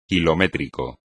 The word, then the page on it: kilométrico: Spanish